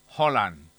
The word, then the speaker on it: Haaland: Además, la letra A suena como una O abierta, mientras que la D final no se pronuncia. – O sea, daría como resultado algo así como Erling Haaland.